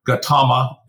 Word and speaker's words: Gautama: Now, you relate a bunch of interesting biography, but there's lots of places you can pick up the biography of Gautama, if that's how you pronounce it.